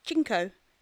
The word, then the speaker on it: CNCO: Yeah, so CNCO, it's pronounced Chinko, as in the Spanish word for five.